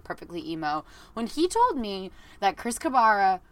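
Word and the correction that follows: Carrabba: Chris Caraba.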